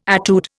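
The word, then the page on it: étude: Indian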